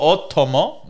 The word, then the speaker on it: Otomo: Speaking of which, the Who Goes For Podcast watched 1988's Akira, directed by Katsuhiro Otomo. – Mm. – Accent on the O, thank you very much.